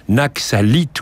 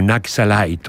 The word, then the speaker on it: Naxalite: Naxalite ou Naxalite, on prononcera alternativement, indifféremment, ce mot en anglais, selon l'accent anglais ou français.